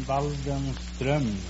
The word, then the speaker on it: Waldenström: No, it's an umlaut on the O. In Sweden, you pronounce the name Waldenström.